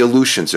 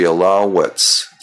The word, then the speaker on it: Alouettes: I used to call them the Aleutians. They're not the Aleutians, the Alouettes. The Alouettes.